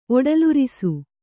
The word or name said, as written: ಒಡಲುರಿಸು